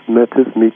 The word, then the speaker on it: metis: And I may not be pronouncing it right, but the word you use is metis. Am I pronouncing it correctly? – Yes, metis. Yes, metis is fine, yes.